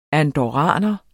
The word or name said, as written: andorraner